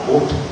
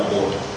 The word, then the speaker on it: Bút: dầu là phát âm theo một cái phương ngữ nào thì âm chuẩn là Bút. Người tổ tiên Việt Nam đã đọc là Bút.